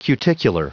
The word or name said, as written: cuticular